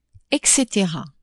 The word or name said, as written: et cetera (etc.)